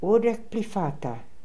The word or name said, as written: Urdekhplifata